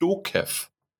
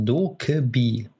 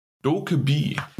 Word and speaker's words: DokeV: Aber wenn er rauskommt, ich werde mir auf jeden Fall mal anschauen, ist Dokev. Mit V geschrieben, Dokev. – Hm, hm wird aber anders ausgesprochen. Ui, wie denn? – Äh, es wird ausgesprochen Dokebi. Dokebi. Dokebi wird es ausgesprochen.